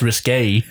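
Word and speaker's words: risqué: That's risque.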